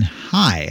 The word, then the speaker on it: Haigh: He's going to be replacing Metropolitan Council Chairwoman Susan High, I believe it's how it's pronounced, H-A-I-G-H, who is retiring after working as the president and the CEO of Twin Cities Habitat for Humanity for more than 13 years.